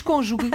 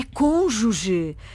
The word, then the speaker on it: cônjuge: É verdade, também não gosto, mas já que temos de dar uma explicação, a palavra, a pronúncia correta, assim é que é cônjuge. – E com acento circunflexo, não é? – Exatamente. É uma palavra esdrúxula. O que é uma palavra esdrúxula? O seu acento tónico recai na antepenúltima sílaba.